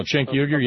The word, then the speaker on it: Cenk Uygur: I saw today on the Huffington Post, I think I sent it to you, the thing from, I never can say his name right, Cenk Uygur. – No, Cenk Uygur, yeah.